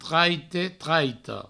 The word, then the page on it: traite, traita: traite, traito